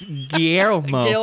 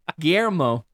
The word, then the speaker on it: Guillermo: Gilmore. – Who's Gilmore del Toro? – Yeah, it's Guillermo. – Guillermo.